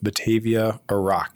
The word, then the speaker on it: Batavia Arak: But I've not really messed around with Batavia Arak. Is that how you pronounce it, by the way? Mm-hmm.